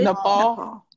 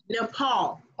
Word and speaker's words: Nepal: NAPAL. Is it Napal or Nepal? What is it? – Nepal. – Nepal. Okay.